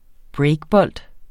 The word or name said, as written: breakbold